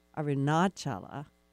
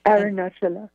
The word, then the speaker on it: Arunachala: Arunachala, I probably said that wrong. – Arunachala.